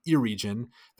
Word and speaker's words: Eregion: And I pronounced it two ways, Eregion and Eregion. The correct pronunciation is Eregion. I just defaulted to my childhood pronunciation of Eregion. You know, when I read it as a kid, I didn't really flip to the appendices to read the dissertation on pronunciation that Tolkien provided for us. So when I saw Eregion, I just pronounced it like any English reader would, Eregion. But it is, of course, Eregion.